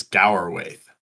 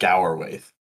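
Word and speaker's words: Gaurwaith: Is that right? Gwarwaith? Gowerwaith. How does... – I think in the audiobook it pronounces Gowerwaith. – Gower. I was going to say, how does Gower Christopher Lee say it? – Yeah, he Gower. – I believe he pronounces it Gowerwaith.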